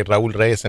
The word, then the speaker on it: Raul Reyes: That's a good way to roll your R's. That's good. – Oh, listen, man, that's I've been proud of that since I was born. I can roll my R's and wiggle my ears. – I have to say my name twice. I say Raul Reyes, and then people kind of go, what?